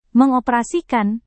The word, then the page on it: mengoperasikan: Indonesian